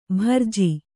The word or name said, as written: ಭರ್ಜಿ